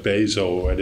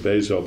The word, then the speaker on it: Bezos: One is Jeff Bezos. – I did that too, yeah. – I didn't realize his name was Bezos. I've been calling him Bezo for all these years, and he pronounces it Bezos, but of Amazon, and that's fascinating.